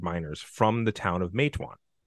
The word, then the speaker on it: Matewan: Matewan. – Matewan.